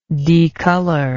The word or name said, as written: decoloration